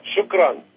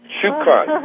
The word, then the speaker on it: Shukran: Shukran. – Shukran. – Shukran, shukran ila. Thank you very much. – Thank you, Hashmir. Shukran. And Palace, I believe it's Shukran.